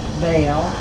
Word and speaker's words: Belle: M-O-L-L-I-E, the initial B, and Sesso is the last name, S-E-S-S-O. – And what does the B stand for? – Bell, Molly Bell. – All right, you want to spell that too? – B-E-L-L-E.